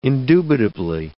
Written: indubitably

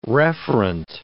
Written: referent

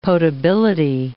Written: potability

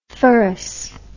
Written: ferous

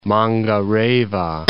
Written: Mangareva

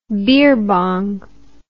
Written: beerbong

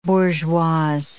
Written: bourgeoise

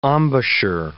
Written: embouchure

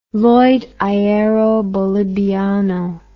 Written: Lloyd Aereo Boliviano

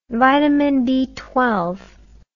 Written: vitamin A aldehyde

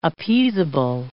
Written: appeasable

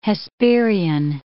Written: Hesperian

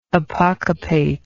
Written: apocopate

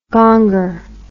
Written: gonger

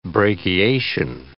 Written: brachiation

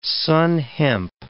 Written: sann hemp

